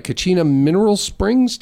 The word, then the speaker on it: Kachina Mineral Springs: Kachina Mineral Springs, did I say that right? – Yes.